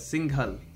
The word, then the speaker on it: Singhal: Well, yeah, there's a couple puns to be had here, but the first thing is, just to clarify, your name, is it like a soft G, and then like you pronounce the H a little bit? – Singhal.